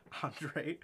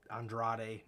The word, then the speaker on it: Andrade: You don't have to say Andrade. Just say Andrade.